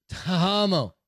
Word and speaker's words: Tahmoh: How do you say T-A-H-M-O-H? – Tamo? – Tamo.